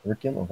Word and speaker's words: orichalcum: Instead of spending gold to get it, you have to get this new, I think it's, I'm not going to murder the pronunciation of this, Orichalcum? – Oh, Auriculum? – Auriculum, wow, okay, there you go. That's way better way of saying it.